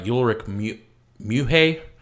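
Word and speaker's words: Ulrich Mühe: And interesting enough, the actor of was played by Ulrich Muhe. If I pronounced that right. – Yep.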